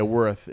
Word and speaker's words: Wirth: Yeah, Worth. It's spelled W-I-R-T-H.